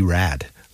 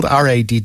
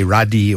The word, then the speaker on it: Radde: Before we dive into out and about a quick housekeeping item, I have learned the pronunciation is Lee Rad. Lee Rad. He is the new Wake Up Crew guy, and uh, it's spelled R A D D E. So the sort of German in me wanted to say Radi or Rade or something like that, but it's Rad, as in radical, which I'm sure he will be.